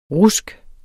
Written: RUSKET

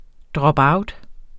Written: dropout

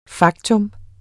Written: faktum

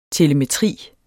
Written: TELEMETRI

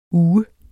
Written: uge

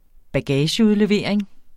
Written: bagageudlevering

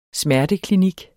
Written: smerteklinik

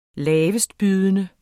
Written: lavestbydende

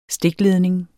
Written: stikledning

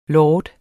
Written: lord

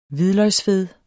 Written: hvidløgsfed

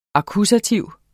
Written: akkusativ

